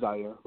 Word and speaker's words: Zaire: And my name is Zaire, by the way, just a small correction.